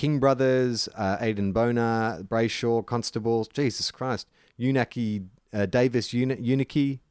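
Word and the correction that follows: Uniacke: Is that how you pronounce it? – Uniacki, I believe. – Uniacki.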